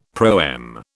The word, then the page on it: proem: British English